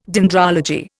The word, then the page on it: dendrology: Indian